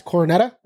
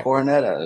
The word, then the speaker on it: Coronetta: It's coronet with an A. – Right. – Coronetta. It's coronetta.